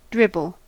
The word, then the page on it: dribble: American English